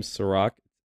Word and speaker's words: Serak: Just type in The Weekly Review, or it might be a little easier to type in my name, Serac, S-E-R-A-K.